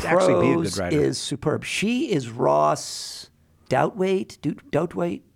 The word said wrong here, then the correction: Douthat: How do you say it? – Douthat.